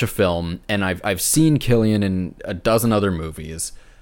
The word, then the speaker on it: Cillian: I can't contest that in the slightest, but I can't pronounce his name, but the lead actor, is it Cillian Murphy or Cillian Murphy? – I believe it's Cillian.